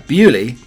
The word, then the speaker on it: Beaulieu: In Hampshire, the uh, village of uh, Beaulieu, with its adjacent palace house, has long been pronounced uh, Bewley, truly, uh, no exceptions made for the French.